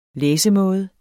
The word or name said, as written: læsemåde